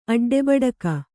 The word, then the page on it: ಅಡ್ಡೆಬಡಕ: aḍḍebaḍaka